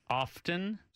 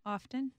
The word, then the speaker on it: often: O-F-T-E-N. How do you say it? – O-F-T-E-N. Often. – Often. – Yeah, that's what you guys say, but that is incorrect. It is often. The T is silent. Do not say often.